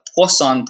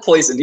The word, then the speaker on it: Poisson: You could actually model this in a statistical or a probability chart called a Poisson process. It's a French name. You can't call it poison, even though it's spelled poison. It's named after a French mathematician.